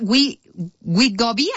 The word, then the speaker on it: Wegovy: hay otro que se llama Wegovy, así se pronuncia.